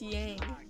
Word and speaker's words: Yang: Yang? It's Yang. – Are you really going to say it like an American? – Well, because this whole thing is gang.